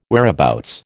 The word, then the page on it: whereabouts: American English